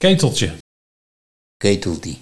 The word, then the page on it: Keteltie: Nieuw lekkerlands